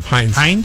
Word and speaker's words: Hynde: Hines. – There's no S. Hines? – Is it? I thought it maybe it's a typo. H-Y-N-D-E. – I thought it was Hines? Chrissy Hine, H-I-N-D-S.